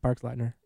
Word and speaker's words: Boxleitner: Boxleitner.